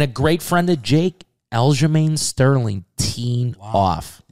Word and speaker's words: Aljamain: Card is absolutely electric this week with Sean O'Malley and how do we say this other guy's name? – Aljamain Sterling, cousin. This guy I just say Sterling, right? – This guy's a fucking fighter.